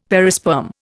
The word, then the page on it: perisperm: Indian